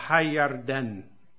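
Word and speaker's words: Hayarden (הירדן): Perché il fiume Giordano in ebraico è una composizione di due parole, infatti in ebraico si pronuncia Hayarden, cioè in italiano Giordano, ma in ebraico Hayarden, e sono due parole, Hayar, che vuol dire espandere, e Den, che sarebbe giudizio, no?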